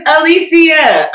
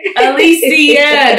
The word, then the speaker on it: Alicia: Do not call me Alicia. My name is Alicia. Okay? Alicia.